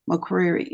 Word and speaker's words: McCreary: My name is spelled Shawanda McCreary, MCCREARY.